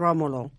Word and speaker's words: Romolo: Is That's it, in Italian. Remo and Romolo. – Is the correct pronunciation.